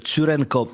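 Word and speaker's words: Tsurenko: Iga Świątek gra kapitalnie na właśnie Roland Garros, jest w drodze po obronę swojego tytułu i dziś powalczy z Ukrainką Łesią Czurenko, czy też Czurenko, jak można bardziej tak wymowę poprawić.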